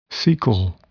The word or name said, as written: cecal